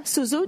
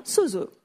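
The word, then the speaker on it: sozo (σώζω): Sozo, dites avec moi, sozo.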